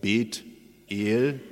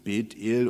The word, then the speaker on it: Bet-El: Bethel, wenn man es richtig hebräisch ausspricht, würde man sagen Bet-El, also in zwei Silben, Bet-El, und das heißt Bet, das Haus, und El ist Gott, also das Haus Gottes, ein Gotteshaus.